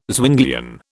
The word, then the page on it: Zwinglian: British English